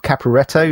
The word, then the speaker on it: Caporetto: How do you pronounce his name? Rob Caporetto? Does that sound right? – That sounds right.